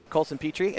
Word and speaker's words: Coulson Pitre: Coulson Pitre.